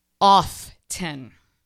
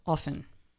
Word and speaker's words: often: It's not often. It's often. The T is silent. We've heard it's, you know, in both ways, but the T is very silent, y'all.